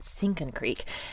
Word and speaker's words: Sinking Creek: Locals pronounce it Sinkin' Creek, and it's a tributary of the current river.